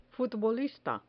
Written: futbolista

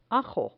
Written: ajo

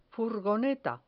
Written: furgoneta

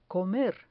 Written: comer

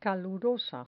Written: calurosa

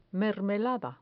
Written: mermelada

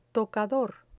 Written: tocador

